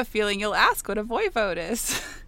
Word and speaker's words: Voivode: I looked up how to pronounce this word. The Voivode of Transylvania. And Voivode.